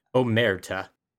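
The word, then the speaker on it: omertà: Well, Alex, it's good to hear that you haven't broken podcasts or Marita. Yeah, I apparently don't know how it's pronounced. Omerta. That, you know, it's Italian.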